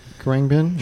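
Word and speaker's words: Khruangbin: So the next artist, I always mispronounce this name. The next artist he recommends is Karangbin. Karangbin? Sure. Am I saying that right? – Mm-hmm. As far as I know.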